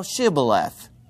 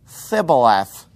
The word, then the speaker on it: Shibboleth: If he said, nay, then said they unto him, say now Shibboleth. And he said, Thibboleth, for he could not frame to pronounce it right.